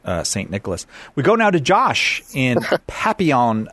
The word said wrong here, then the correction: Papillion: It's Papillion, but that's okay.